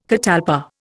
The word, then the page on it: catalpa: Indian